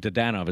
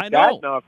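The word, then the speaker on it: Dadonov: I want to get into the Brady Tkachuk thing, but before we dive into that spiciness, Evgeny Dodonov, and Dodonov is the way that we're saying it here in Vegas, what is Pete DeBoer getting in this player? – Boy, first of all, I don't know how to say his name now because he was Dadnov here. – I know. I've always said Dadnov.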